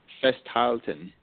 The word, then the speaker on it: Festhalten: And Festhalten.